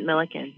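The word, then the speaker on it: Millikin: Yeah, at Millikan.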